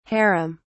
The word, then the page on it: haram: British English